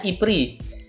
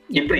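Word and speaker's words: E-Prix: Kayak event-event udah mulai ya kan, kayak kemarin barusan banget tuh ada Aprix. Bacanya IPRI. – IPRI bacanya ya? X-nya nggak dibaca ya? – X-nya nggak dibaca, IPRI di Ancol ya kan.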